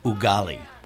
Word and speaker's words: ugali: So you're serving ugly, Mr. Whittaker? I was just reading about it. – Well, I'm offering some international dishes in honor of Jason's return. – It's actually pronounced ugali.